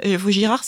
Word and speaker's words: Vaugirard: Enfin voilà, euh, je me souviens de l'avoir vu déchiffrer rue de Vaugirard et de me dire Vaugirard, c'est comme canard. – Il y a un D à la fin et ça se prononce pas.